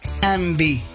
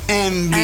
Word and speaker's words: Yngwie: Porque su nombre es Envy. Así se pronuncia, Envy.